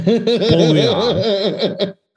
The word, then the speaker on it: Baudrillard: You got to work on the pronunciation. Baudrillard.